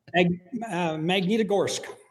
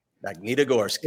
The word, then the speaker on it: Magnitogorsk: Magnitogorsk. – Magnitogorsk.